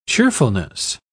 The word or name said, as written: cheerfulness